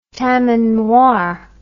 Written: tamanoir

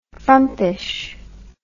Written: frumpishness

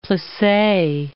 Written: plisse

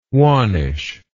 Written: wannish

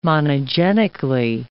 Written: monogenically

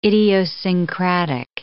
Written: idiosyncratic

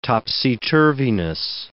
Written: topsy-turviness

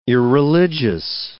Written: irreligious

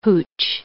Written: hooch

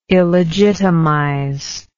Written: illegitimize

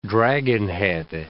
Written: dragonhead

